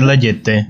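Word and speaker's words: La Jetée: ¿Es la Yeti? ¿Yeti? ¿Algo así? Yo tengo entendido que es la Yeté.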